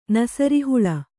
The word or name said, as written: ನಸರಿಹುಳ